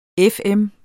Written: FM

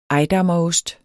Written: ejdammerost

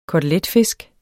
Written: KOTELETFISK

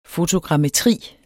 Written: fotogrammetri

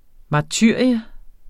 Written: martyrium

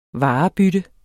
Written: varebytte

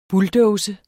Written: bulldozer

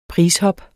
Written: prishop

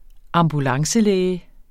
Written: ambulancelæge

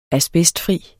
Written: asbestfri

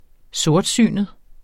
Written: sortsynet

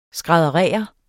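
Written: skrædderer

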